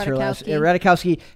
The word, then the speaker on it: Ratajkowski: Ratajkowski, had the most perfect lips.